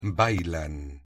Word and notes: bailan: Spanish

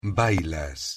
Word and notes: bailas: Spanish

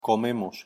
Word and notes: comemos: Spanish

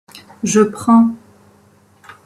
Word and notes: je prends: French

